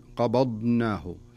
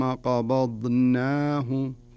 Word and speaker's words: قبضناه: لكن الصحيح أن تنطق الضاد سليمة صحيحة ثم تنتقل إلى النون وتنطقها أيضا سليمة. ثم قبضناه قبضنا